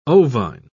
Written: ovine

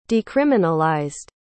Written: decriminalized

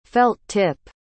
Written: felt-tip

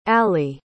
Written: alie

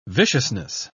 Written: viciousness

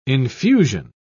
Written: infusion

